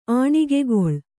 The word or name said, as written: ಆಣಿಗೆಗೊಳ್